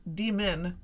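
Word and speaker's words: D.Min.: But notice when you say demon, the accent is on the D, and when you say demon, the accent is on the min.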